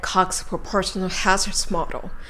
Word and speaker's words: Cox proportional-hazards model: では続いて、 Cox Proportional Hazards Model と発音されますので、よーく注意して聞いてみましょう。 – And for those of you who've heard of Cox Proportional Hazards Models, I know はい。